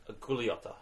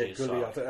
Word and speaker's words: Gugliotta: Gianluca, I think it's pronounced Gugliotta. I think. – Yeah, Gugliotta. I thought it might be.